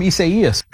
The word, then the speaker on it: Isaias: Tropical Storm Isaias.